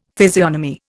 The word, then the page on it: physiognomy: Indian